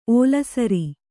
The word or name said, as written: ಒಳಸಾರ